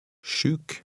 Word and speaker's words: Sjuk: The letter combination SJ has the same sh sound. Read number 14. – Sjuk.